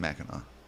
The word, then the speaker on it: Mackinac: No, it's got a C, but it's pronounced Mackinac. Mackinac. Mackinac.